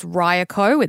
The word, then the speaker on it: Ryeqo: We believe it's pronounced Ryeqo. It's new, so we're still learning the pronunciation, spelled R-Y-E-Q-O.